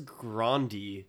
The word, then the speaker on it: Grande: She says Grande.